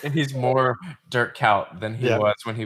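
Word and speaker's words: Kuyt: I said Coit. I'm sorry. Kout. Dirk Kout. My bad.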